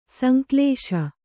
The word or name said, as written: ಸಂಕಲಿಸು